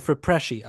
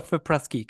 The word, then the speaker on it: FIPRESCI: But Steiger does win Best Actor, and it's the runner-up for the Fipreschi Prize, which I or Fipresci, I guess, would be, well, no, Fipreschi, because it's Berlin. I was thinking Venice for a second, the Italian pronunciation of that, because it looks like an Italian word.